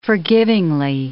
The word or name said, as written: forgivingly